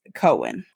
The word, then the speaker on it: Cohen: It is Cohen. Cohen. Yeah, Cohen. Yeah, that's how you say it.